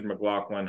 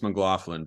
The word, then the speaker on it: McLaughlin: It is pronounced Mclaughlin, but don't worry, McLaughlin. You're only about the 879th McLaughlin. Person McLaughlin. To make that misstep.